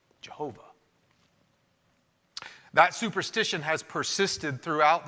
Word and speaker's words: Jehovah: And so what you would get for YHWH would become YAHOWIH, or Yahowah, or if you pronounce the Y as a J, it would become Jehovah or Jehovah, like Jehovah's Witnesses.